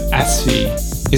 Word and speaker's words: Asfi: I mean, the closest pronunciation would be Asfi, as in us as in like, you know, we and us, and then fi, F-E-E.